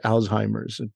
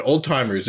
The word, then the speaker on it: Alzheimer's: my grandfather had Alzheimer's disease, Alzheimer's. I'm like, well, that's not quite the pronunciation. I don't think the German was the Z turns into a T.